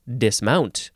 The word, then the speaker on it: dismount: Uh, in this case, you would actually emphasize the second syllable, dismount. First form, verb from 1566, starting with transitive.